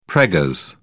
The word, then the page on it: preggers: British English